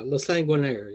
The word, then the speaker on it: La Sanguinaire: So this is going to be based on your book, The Web of La Sanguinaire. That's how it's pronounced, right? – Yeah, La Sanguinaire. Yeah, it's French for the bloodthirsty.